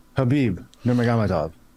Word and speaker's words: Nurmagomedov: Nurmagomedov.